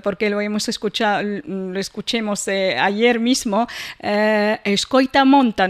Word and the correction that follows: Escoita a montaña: no sé si lo pronuncio bien. – Escoita a montaña.